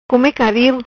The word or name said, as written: Caril come